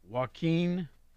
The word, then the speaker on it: Joaquin: Joaquin Phoenix? Is that how he pronounces his first name? – Yes.